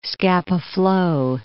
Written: Scapa Flow

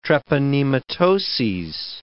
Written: treponematoses

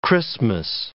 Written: Christmas

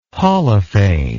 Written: holophane

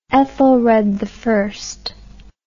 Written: Ethelred I

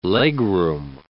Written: legroom

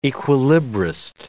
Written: equilibrist

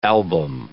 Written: album

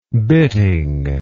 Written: bitting

